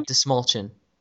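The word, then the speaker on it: Dastmalchian: David Desmalchin. – Is that how you pronounce it? His last name? – Yeah, Desmalchin.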